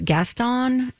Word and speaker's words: Gaston: We've got Joshua Henry is Gaston.